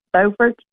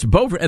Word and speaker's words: Beaufort: Let's go to, is it Beaufort, North Carolina? Did I pronounce the name of your town correctly? – Yeah, Beaufort. – So it's Beaufort.